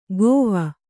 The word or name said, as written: ಕೋವ